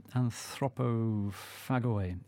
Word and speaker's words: Anthropophagoi: I hope that's the right way to pronounce it. Anthropophagoi, yeah.